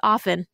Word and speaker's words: often: Or some people say often. It's just often. The T is silent.